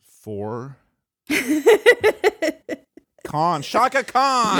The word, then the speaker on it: Khan: Let me pronounce that correctly. Khan.